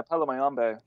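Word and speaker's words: Palo Mayombe: Yeah, Paulo Mayombe.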